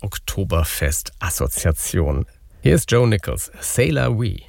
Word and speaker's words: C'est la vie: Also der neue Song von Joe Nichols heißt C'est la vie, also amerikanisch ausgesprochen C'est la vie, auch so geschrieben.